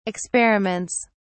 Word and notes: experiments: British English

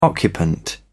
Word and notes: occupant: British English

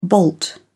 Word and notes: bolt: British English

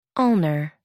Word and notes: ulnar: American English

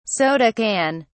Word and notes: soda can: British English

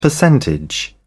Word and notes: percentage: British English

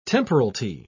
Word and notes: temporalty: British English

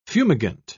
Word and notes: fumigant: British English

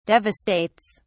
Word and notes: devastates: British English

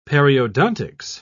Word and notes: periodontics: British English

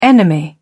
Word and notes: enemy: British English

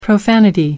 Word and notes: profanity: American English

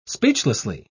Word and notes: speechlessly: British English

